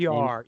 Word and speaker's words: Magyar: Derek Magyar, I guess that's how you say it. Magyar. Yeah, I just looked him up.